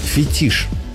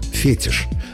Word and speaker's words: фетиш: В этом слове ударение иногда ставят по ошибке. Переносят на первый слог – фетиш. Но литературная норма однозначна, и словари единодушны – фетиш с ударением на последнем слоге. Это объясняется происхождением. Слово пришло в русский язык из французского, где ударение обычно падает на последний слог. Русский вариант это ударение сохранил – фетиш.